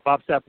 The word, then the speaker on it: Bob Sapp: Bob Sapp, welcome to the Two-Man Power Trip. – Oh, well, thank you very much. And you said it right, but it's Bob, the Beast.